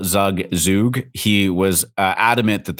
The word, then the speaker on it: Zug: And they very adamantly said, "It is Zug. There's no questions about it.